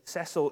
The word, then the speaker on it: Cecil: And Kath pointed out yesterday that Cecil is not a hard C, so it doesn't work as well.